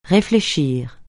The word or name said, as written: réfléchir